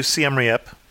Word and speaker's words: Siem Reap: So you went to Siem Reap. Am I saying that correctly? – That's correct, yeah.